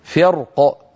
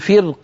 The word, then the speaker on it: فرق: فكان هؤلاء ينطقون هذه الكلمة فرق مفخمة الراء، وكان بعض القبائل ينطقها مرققة لأن القاف قد كسرت فيقول فرق كالطود، فرق كالطود مرققة، سأنطقها مفخمة ثم أنطقها مرققة.